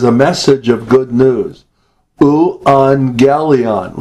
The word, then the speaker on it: euangelion: When you get two gammas together like that, the first one is pronounced like an N, and the second one has the G. So it's euangelion.